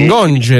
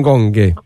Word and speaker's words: Ngonge: Quindi Ngonge, non Gonghe.